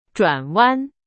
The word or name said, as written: 转弯